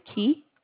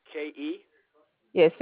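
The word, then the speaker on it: Ke: It is Key, K-E. – K-E? – Yes, sir. It's pronounced Key.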